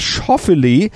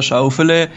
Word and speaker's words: Schauffele: Naja, dann wäre der Alexander Schauffele. Das wurde auch sicher über viele Dekaden so ausgesprochen, weil tatsächlich seine Familie aus Stuttgart kommt.